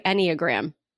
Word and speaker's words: Enneagram: It's Enneagram. Okay. Is it that's right? Enneagram.